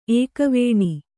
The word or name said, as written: ಏಕವೇಣಿ